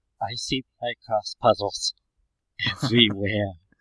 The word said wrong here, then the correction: Picross: I think it's Picross.